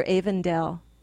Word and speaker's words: Avondale: Is it a long A or a short A? Avondale or Avondale? – It's Avondale.